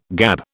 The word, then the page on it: gab: British English